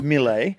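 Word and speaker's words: Mile: Well, it's spelled Smile. But it's Mille and a very good player for the Socceroos.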